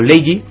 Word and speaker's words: Leiji: de Reiji, o Leiji, como se pronuncia en Occidente, que en japonés significa Guerrero Cero.